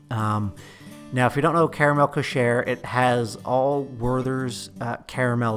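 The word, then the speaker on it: Karamell-Küche: We're stopping in Caramel Coucher. I think that's how you say it.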